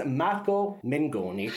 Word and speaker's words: Mengoni: He's singing L'Essenziale, and his name is Marco Mengoni.